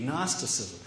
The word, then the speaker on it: Gnosticism: And they're referred to generally as Gnostic Gospels because they teach something that can be generally called Gnosticism, spelt with a G, Gnosticism.